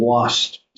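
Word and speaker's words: Lhoist: called the WAST. That's how you pronounce it. But it looks like El Hoist.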